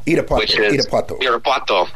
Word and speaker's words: Irapuato: Irapuato, if I'm saying that right, Mexico. – Irapuato.